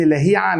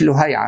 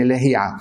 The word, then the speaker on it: لهيعة: واسمه ابن لهيعة مش لهيعة عشان في ناس بتنطقه بضم اللام، هو بفتح اللام وكسر الهاء ابن لهيعة.